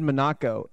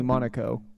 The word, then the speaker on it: Monaco: You said Monaco, and it's definitely Monaco.